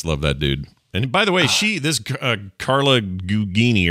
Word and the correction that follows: Gugino: or how you say her name? – Gugino, yeah.